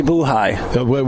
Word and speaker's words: Buhai: Did I get her name right? – Ashley Buhai.